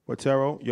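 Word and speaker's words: Forterro: So you're the CEO, correct me if I'm saying this wrong, you're the CEO of Fortenero. – Fortero, your partner in Courtin Capital, and you're chairman in FFG.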